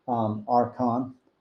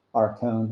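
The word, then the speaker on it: archon (ἄρχων): And you'll often hear archon, but it is actually archon. It's the omega, is the vowel there in the last syllable.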